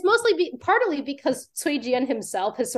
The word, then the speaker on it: Cui Jian: By Kui Jiang. – Yes, so the C would be pronounced like a ts, t so so Cui Jian is how we would pronounce that his name.